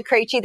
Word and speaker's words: Krejci: My name is Martha Krejci. That's how you pronounce all of those consonants really close together, right?